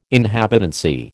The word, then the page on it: inhabitancy: American English